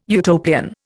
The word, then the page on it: Utopian: Indian